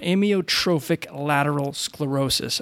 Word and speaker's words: amyotrophic lateral sclerosis: I was just about to try to pronounce ALS, which is amyolateral. I always screw that one. Amyotrophic lateral sclerosis.